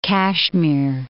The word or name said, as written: Kashmir